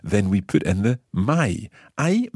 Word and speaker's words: mai: It sounds a little funny because we've got the hai, the part of avere for tu, and then mai, which rhymes with it.